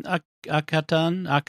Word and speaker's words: Akhaten: Akhaten.